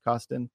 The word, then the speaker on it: Karsten: It looks like Karsten Woodhouse, pronounced Costin.